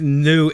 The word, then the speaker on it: Nieux: But no one's trademarked new N-I-E-U-X. So I think, you know, yes, the new society is spelled N-I-E-U-X with a little French New Orleans flair.